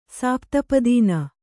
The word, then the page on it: ಸಾಪ್ತಪದೀನ: sāpta padīna